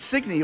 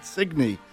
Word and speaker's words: Signe: Signe? – That's right, Signe. It was it's a Danish name, and they pronounce it Signe.